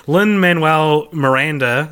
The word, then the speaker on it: Lin-Manuel Miranda: And this is what actor/writer/100 other things Lin-Manuel Miranda, is that how you say it? – Yep.